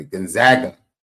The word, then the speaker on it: Gonzaga: Gonzaga.